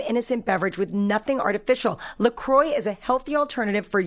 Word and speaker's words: LaCroix: It's LaCroix Sparkling Water. By the way, I used to pronounce it LaCroix, like the designer, but that would be wrong. It's LACROIX. Have you seen it? It's pronounced LaCroix.